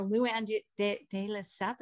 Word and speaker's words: de Lesseps: So the Bravo rebooting of New York City, Ramona Singer, Luann de Lesseps, is how you say it.